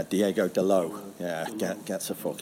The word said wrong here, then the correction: Diogo: Is it? It's Diogo.